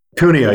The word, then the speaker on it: Cuneo: Cuneo, you got it.